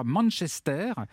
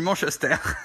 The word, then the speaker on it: Manchester: Donc, vous êtes né, vous avez grandi là-bas dans le quartier qui s'appelle, je crois, Manchester, à Charleville-Mézières. – On ne le prononce pas avec cet accent, avec cet accent anglais. – On le dit comment? – Mais oui, c'est ça. Ah, on dit Manchester.